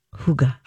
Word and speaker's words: hygge: Hygge, HeigE is I, actually yeah.